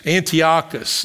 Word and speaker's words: Antiochus: This is Antiochus. That's the way I pronounce his name.